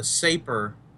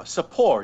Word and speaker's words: Sapor: Have any of you heard of the Saper RTA? – Who makes it? – Watofo. – Oh, yeah. Sapor, you mean. – Sapor, yes. Sapor. Saper. – It's spelled like vapor with an S.